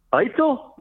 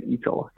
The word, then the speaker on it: Eitzel: I didn't even know how to pronounce it. I was like, oh, is Mark Eitzel there? And the guy's like, Eitzel? I'm like, yeah, that's what I meant to say. I actually said Eitzel, like a total bonehead.